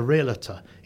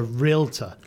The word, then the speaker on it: realtor: And the first thing I would say, and I don't mean to be critical, is that it's not a realtor, it's a realtor. – Realtor. – It's just, yep. It's yep. Just two syllables.